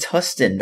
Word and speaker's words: Huston: So I'm not, yeah, so Matt Hudson. – It's Huston, not Hudson.